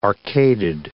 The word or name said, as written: arcaded